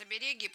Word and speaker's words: оберег: игрушек, и сегодня наша тема Китай, китайские игрушки, и вот тут, внимание, где будем ставить ударение в слове оберег? В принципе, это правильное место для ударения, оберег и не оберег, но все чаще говорят обереги, поэтому, может быть, когда-нибудь это станет нормой, но грамматически правильно оберег.